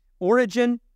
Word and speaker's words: Origen: Origen, That's how you would pronounce that.